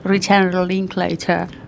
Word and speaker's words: Linklater: Parece que estoy pero es así. Richard Linklater. Es así, lo pronuncio.